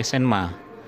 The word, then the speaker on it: Semir: Oh ya, ternyata tulisannya Semir bacanya Senma, jadi bukan Semir sepatu, benar Senma.